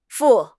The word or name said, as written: fu4